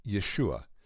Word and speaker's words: Yeshua: In Hebrew, it's pronounced Yeshua.